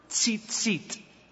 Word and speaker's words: tzitzit: The name of this tassel in Hebrew is the word tzitzit.